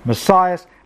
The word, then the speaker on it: Messias: Now, when the Hebrew word that becomes a Greek transliterated word spelt M-E-S-S-I-A-S is in transliterated English, I shall anglicize it to Messias, and when it is in Greek, I shall pronounce it as Messias.